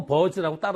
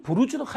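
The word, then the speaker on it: Burj: 그 버즈 아랍이라고 했는데, 버즈가, 좀 이따 보니까 또 버즈 칼리파가 생겼는데, 그건 또 부르즈르 칼리파라고 읽더라고요. 그래서 가만히 생각해 보니까 아마 서양권에서, 영어권에서 먼저 깨달은 것 같아요. 아, 이거를 버즈라고 읽으면 안 되는구나. 부르즈로 읽어야 되는구나. 라고 해서 칼리파는 또 부르즈 칼리파라고 읽었어요.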